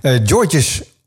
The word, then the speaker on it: Georges: Hoe spreek je? Georges op zijn Frans uit. Georges? Ah, joh, ik maak ook niet uit.